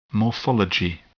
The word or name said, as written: morphology